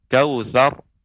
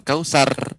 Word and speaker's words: كوثر: maka ra dibaca tebal kautsar bukan kausar ya kautsar wanhar ya abtar bukan abtar ya demikian demikian ya bacaan surat Al-Kautsar.